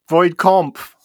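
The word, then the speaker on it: Voight-Kampff: How'd you pronounce it? – Void Comp. – Comp. That A should definitely be an O, man.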